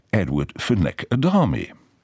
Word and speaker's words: Edward Fenech Adami: Und ich erfahre, oh, this is Edward Fenech Adami.